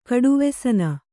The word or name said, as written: ಕಡುವೆಸನ